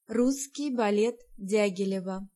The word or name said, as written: Ballet